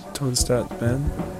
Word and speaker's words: Tonstartssbandht: How do you actually pronounce it? Is it Tone Start Band? – Tone Start Band. Yeah.